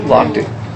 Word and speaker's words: Lochte: Lochte.